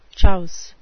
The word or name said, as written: chouse